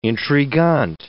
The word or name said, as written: intriguant